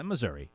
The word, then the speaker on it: Missouri: I say Missouri, but yeah. I do know some people who say Missouri. – Right, yeah, Missouri. Yeah, Missouri. Let's keep this simple. It's Missouri.